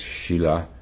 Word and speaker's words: Sila: These three are Shila, S-I-L-A, it's pronounced Shila, which means morality or discipline, conduct.